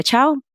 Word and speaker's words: Chou: So again, my name is Gloria Chou. You can find me on all the things at Gloria Chou PR.